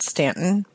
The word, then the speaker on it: Staunton: The locals pronounce it Stanton, but there's an OU in there.